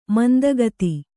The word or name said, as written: ಮಂದಗಾತಿ